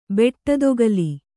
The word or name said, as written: ಬೆಟ್ಟದೊಗಲಿ